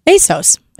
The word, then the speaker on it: ASOS: Well, that's what it says, pronounced ASOS, A-Y-S-O-S. ISOS? – ASOS. – ASOS? – That's right.